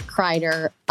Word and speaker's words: Kreider: I am joined today by the great Nick Kreider of BLEAV, co-host of The Charity Stripe.